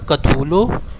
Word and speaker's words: Cthulhu: I mean, it's pronounced more of a Cthulhu.